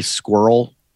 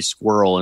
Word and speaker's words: Scurll: And Did you say Marty Scurll? – Scurll. Marty Scurll. Did I say it wrong? – I think you're saying it right. It just sounds like you're saying Marty Squirrel instead of Marty Skrull.